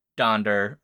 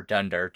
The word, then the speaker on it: Donder: So the eight reindeer, right, are Dasher, Prancer, Vixen, Comet, Cupid, Donder or Dunder, depending on your pronunciation.